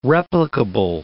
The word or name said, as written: replicable